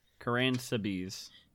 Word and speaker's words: Karánsebes: I say that right? – Nope. – What are the spell it correctly? Karan Sabiz.